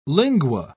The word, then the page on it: lingua: British English